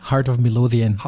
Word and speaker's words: Heart of Midlothian: Fußballer warst du auch, du hast ja ganz geniale Clubs genannt, Hearts of Miss Lothian zum Beispiel, wenn ich es jetzt halbwegs richtig ausgesprochen habe, eine der Top 5 Mannschaften in Schottland. – Heart of Midlothian.